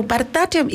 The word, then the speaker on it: Bharatanatyam: So, for listeners who may not be familiar with Bataranium, which I don't think I'm actually saying that right